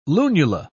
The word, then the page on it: lunula: British English